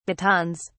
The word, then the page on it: batons: British English